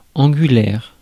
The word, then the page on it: angulaire: France; IPA: [ɑ̃.ɡy.lɛʁ]; French